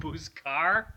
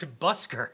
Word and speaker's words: busker: Buscar? – Okay, Glass. Google. – Definition. – Definition buscar. Is it B-U-S-C-A-R? – No, it isn't buscar. It's a busker. – I'm saying it right.